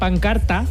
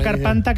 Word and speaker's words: pancarta: Sacaron una pancarta... Mira, lo he dicho bien, siempre digo carpanta. – Ah, es verdad, claro. – Sacaron una carpanta que podía leerse: las Malvinas son argentinas.